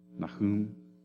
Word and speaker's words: Nahum: You remember how you say his name in Hebrew? Nahum.